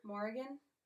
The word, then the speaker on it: Morrigan: How do you pronounce her name? – Morrigan. – Morrigan. – It's Morrigan? Like there's a little bit of like a draw to because of the spelling, but it's pretty much Morgan.